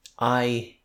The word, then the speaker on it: Jai: And I Hindley, his name is spelled J-A-I, but it's spoken like I.